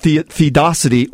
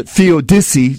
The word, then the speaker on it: theodicy: And it's called theodicy, or theodicy, some people would pronounce it.